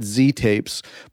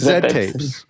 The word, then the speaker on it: Z-Tapes: I've always in my head, I've looked at your label, I've always called it Z-Tapes, but that's dumb because I'm Canadian and I say Zed. Only Americans say Z, and I imagine you say Zed, right? Is that right? – Yes. – So it's Zed Tapes.